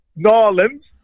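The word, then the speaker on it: New Orleans: It's great to hear that you'll be going to Nawlins, as we pronounce it as, this weekend.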